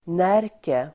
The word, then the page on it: Närke: [n'är:ke]